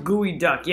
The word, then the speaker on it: geoduck: It's pronounced gooey duck.